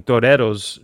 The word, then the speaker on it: Toreros: And I noticed she was wearing, I'm going to do it in the Spanish pronunciation, Toreros jersey.